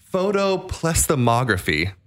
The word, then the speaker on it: photoplethysmography: Well, this uses a science called, I'm going to try to pronounce this properly, photoplethysmography is the correct way to pronounce it.